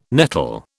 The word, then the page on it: nettle: British English